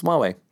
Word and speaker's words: Huawei: It's Huawei.